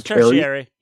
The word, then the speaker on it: tertiary: tertiary? I think I'm mispronouncing this, but tertiary degree. – Spell it. – T-E-R-T-I-A-R-Y. – No, that's tertiary. Yeah, that's tertiary. You had it right.